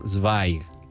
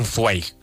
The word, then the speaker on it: Zweig: Zweig, que según parece se pronuncia así el apellido. – Stefan Zweig, para, para todos.